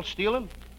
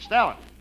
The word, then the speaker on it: Stalin: Marshall Stealing. – Marshall Stealing? You mean Stalin? – I'm using the European pronunciation.